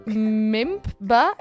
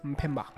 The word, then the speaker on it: Mpemba: What is the MEMBA effect? I think that's how you pronounce it. It's M-P-E-M-B-A. – Mpemba.